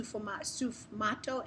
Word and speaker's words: sfumato: He talks about dealing with ambiguity, which is, I believe it's pronounced sufumato, S-F-U-M-A-T-O.